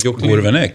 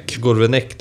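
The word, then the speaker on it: Gourvennec: Gourvenec. – Gourvenec, det är så enkelt. Gourvenec.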